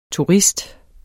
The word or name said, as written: turist